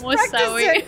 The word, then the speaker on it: Al-Mousawi: Just Al-Mousawi.